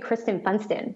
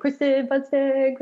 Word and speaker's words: Kristin Funston: And then when my husband and I got engaged, then everybody figured out like, oh, wait, it's going to be Kristin Funston. Like it almost kind of rhymes. And so they would go around saying, Kristin Funston, Kristin Funston.